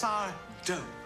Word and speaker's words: Sardo: Okay, Mr. Sardo. I'll take... – It's Sardo. No mister, accent on the do.